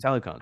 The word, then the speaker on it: Talokan: Talokans? I guess we can call him that. Talokan. How do you say it? Talokan. – Talokan? Talokan. – Talokans. Talokans. – Talokans. – Like Peloton.